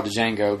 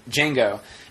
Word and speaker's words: Django: Sarah wasn't annoyed when we saw Django, but I don't... – Django. Yeah. Not the Django. – I know. It's just like the trailer says, it's the D is silent.